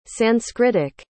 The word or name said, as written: sanskritic